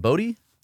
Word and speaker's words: Botte: Is it Botte? – Yeah. – Botte, is that how you say it? – You said it perfectly.